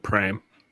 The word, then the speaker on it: Paraparaumu: Okay, locally, no, locally they don't even say Parapa. It's just Pram. – Wait, what? Where is this? What is this? – It's spelled Paraumu, and people pronounce it Pram.